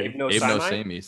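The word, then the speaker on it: Abe no Seimei: So it's A-B-E-N-O-S-E-I-M-E-I. Abe no say me. What? How do you say it? – That sounds, yeah, I think you just know. Yeah, that sounds right to me. Right. – No, yeah. Abe no say me sounds right.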